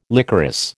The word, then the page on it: licorice: American English